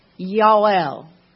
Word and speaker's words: Yael: Yael is how it's pronounced in Hebrew. Yael.